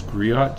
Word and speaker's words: Griot: And most of the people that play that instrument come from what a family that, I might not say this right, but it's Griot, G-R-I-O-T.